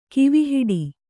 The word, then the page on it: ಕೂವೆಹಿಟ್ಟು: kūve hiṭṭu